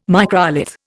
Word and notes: microlith: Indian

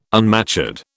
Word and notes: unmatured: British English

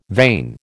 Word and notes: vane: American English

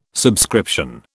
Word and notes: subscription: British English

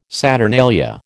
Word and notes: saturnalia: American English

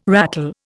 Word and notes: rattle: Indian

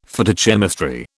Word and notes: Phytochemistry: British English

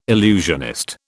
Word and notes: illusionist: British English

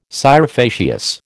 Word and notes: scire facias: American English

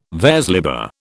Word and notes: vers libre: British English

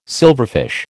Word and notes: silverfish: American English

